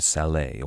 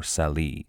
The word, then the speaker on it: Salé: They arrived at a city named Salé, or Sali. The English pronounced it much like the name Sally.